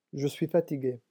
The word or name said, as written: je suis fatigué